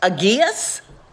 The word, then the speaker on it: Agius: Agius? – Okay. – A-G-I-U-S? – Yes.